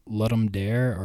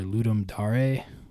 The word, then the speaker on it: Ludum Dare: But generally the big ones that I pay attention to are called the Let 'em Dare or Ludum Dare. I've heard people pronounce it both ways, but that's the big game jam that happens twice a year that I pay attention to.